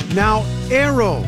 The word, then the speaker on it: Naoero: This program is previously called the country Nauru, N-A-U-R-U. The 11,000 residents have altered Nauru to be Nauero, N-A-O-E-R-O. They're going back to the pronunciation in their native language and losing a pronunciation used by outsiders.